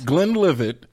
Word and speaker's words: Glenlivet: Glenlivet.